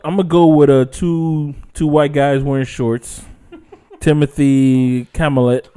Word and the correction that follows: Chalamet: Chalamet. How you fucking pronounce that? – Chalamet?